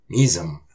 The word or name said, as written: Misum!